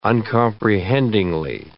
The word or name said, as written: uncomprehendingly